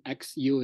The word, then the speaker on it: Xu: As you can see, that the last name XU is quite often difficult for people to pronounce.